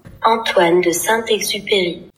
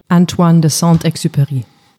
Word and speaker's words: Antoine de Saint-Exupéry: Antoine de Saint-Exupéry. Antoine de Saint-Exupéry.